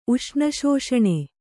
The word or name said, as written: ಉಷ್ಣಶೋಷಣೆ